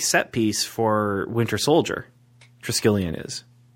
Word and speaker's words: Triskelion: So in the Ultimate Universe, S.H.I.E.L.D.'s headquarters, and I'm going to mispronounce it, is called the Triskelion. Oh, man, that was – Triskelion? – Triskelion.